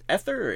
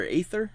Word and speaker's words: Aether: How do you pronounce it? Is it Aether or Aether? – I say Aether Nexus.